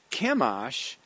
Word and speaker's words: Chemosh: Chemosh is spelled C-H-E-M-O-S-H, Chemosh. And Moloch is spelled M-O-L-E-C-H.